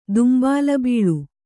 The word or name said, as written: ದುಂಬಾಲಬೀಳು